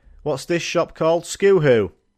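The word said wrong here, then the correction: Schuh: Apparently, this shop is known as Shoe, but it's not spelled like that.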